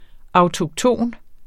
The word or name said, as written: autokton